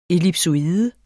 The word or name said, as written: ellipsoide